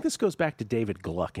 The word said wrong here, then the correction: Glick: in chess. – Glick. – Glick. Is it pronounced Glick? – Yeah.